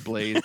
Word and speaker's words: Blaz: I think it's still pronounced Blaze, but it's spelled BLAZ.